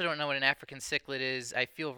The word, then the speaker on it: cichlid: We've also got Kangaskhan, and I feel like that one might be the odd one out, and I'm guessing that's the African cichlid? – Yep, you're pronouncing it correctly.